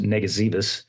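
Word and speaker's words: Ngesebus: But, Bill, the 5th Marines go off, and we talked about this just very, very briefly, I think, in episode one, because it's an island that's hard to pronounce. It's called pronounced Negazivus.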